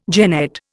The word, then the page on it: jennet: Indian